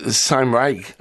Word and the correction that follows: Cymraeg: am I pronouncing this right? Can you tell us about the Māori? Or look, maybe you should say it, and then I'll get a handle on it. – Oh, Cymraeg, yeah, which is the Welsh language.